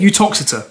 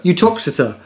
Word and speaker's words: Uttoxeter: How do you pronounce it? – Uttoxeter. – Uttoxeter.